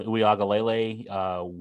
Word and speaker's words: Uiagalelei: The four teams we said we like, the three over in the Atlantic, Clemson, Wake, and NC State, with you know, Clemson with Uiagalelei, Wake, and I can't believe I said that right the first time.